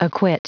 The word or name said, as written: acquit